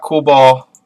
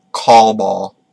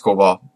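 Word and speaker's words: Coolbaugh: That's how you say it. Please let me tell you. I hope it's not like call ball. – Oh God! I'm not gonna even say his name. – I'm pretty sure it's Coolball, but he has been hired to be the hitting coach for the Orioles after Jim Presley stepped down due to personal reasons.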